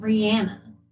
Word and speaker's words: Rihanna: I learned recently that Rihanna's name is actually supposed to be pronounced Rihanna.